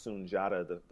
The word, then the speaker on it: Sunjata: Even though it looks like Sundiata, and a lot of people actually go, that have that name, go by Sundiata, mm-hmm. and Mandinka is pronounced Sunjata.